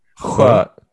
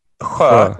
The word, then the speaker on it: sjö: It's spelled S-J and then A, which is an O with two dots over it. Sjö. So the S-J... The SJ is pronounced or and then the O is an so yeah, you got it.